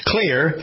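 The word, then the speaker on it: Xlear: Clear, you pronounce it Clear, but it's spelled X-L-E-A-R. X-L-E-A-R.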